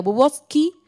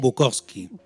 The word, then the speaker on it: Bukowski: Eh, a Galiano y a Bubovsky Y discos dice... – Bukowski.